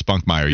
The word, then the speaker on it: Spunkmeyer: It's actually Otis Spunkmeyer. You're right. Spunk. So I put the N in it, and I apologize. Yeah, for some reason I saw that extra. I made it another syllable. I didn't mean to. It's Taysom Hill all over again. It's Taysom Hill, and it's Otis Spunkmeyer, not Otis Spunkinmeyer.